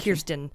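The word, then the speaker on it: Kirstjen: Yeah, Christian with a J. – Kirsten, it's very. – It's Kirsten. – Kirsten? Oh, the J is silent.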